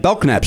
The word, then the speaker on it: Belknap: That's what our friend Matt Belknap said. – Exactly. – Yeah. – And I've just been told today the K is silent in Matt's name.